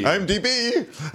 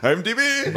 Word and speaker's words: IMDb: I'm just pronounced IMDb as I am, and it's not. It's like, I am DB. – I am DB. – IMDb. IMDb.